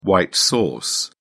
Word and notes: white sauce: British English

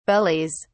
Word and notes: bellies: British English

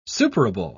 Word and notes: superable: British English

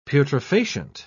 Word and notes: putrefacient: British English

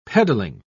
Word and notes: peddling: British English

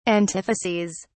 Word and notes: antitheses: British English

